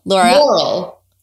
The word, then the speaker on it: Laurel: Laurel.